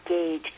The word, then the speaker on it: Gage: And by the way, my last name is Gage, G as in George, A-G-E.